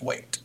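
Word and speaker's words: Whaite: But we do know that the celebrity for that male pairing will be John, I believe it's pronounced Waite. – Yes, that's how I'm calling it.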